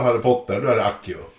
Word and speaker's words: Accio: The classical pronunciation would have been accio. Church Latin, which developed largely in parallel with the pronunciation of Italian, would be axio. Either would probably work fine.